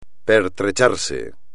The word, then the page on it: pertrecharse: espagnol